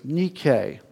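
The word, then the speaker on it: Nike (νίκη): And in the noun tense, it is pronounced as Nike. And this word is spelled N-I-K-E, which we get our word Nike from.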